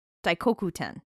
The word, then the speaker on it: Daikokuten: Daikokuten.